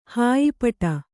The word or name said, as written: ಹಾಯಿಪಟ